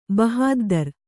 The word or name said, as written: ಬಹದ್ದುರಿ